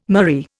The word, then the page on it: murrey: Indian